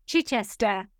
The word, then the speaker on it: Chichester: Is a senior lecturer in marketing and digital marketing at the University of Chichester. I don't know if I'm saying that right, so you can... – Chester. – Okay, thank you very much. Say that again. – Chichester.